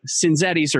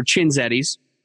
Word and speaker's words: Cinzetti's: It has one C, and you pronounce it, you're supposed to pronounce it with a CH, even though there's not an H. But Cinzetti's or Cinzetti's is an Italian buffet in northern Denver, just north out of Denver.